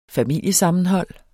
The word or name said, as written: SAVNET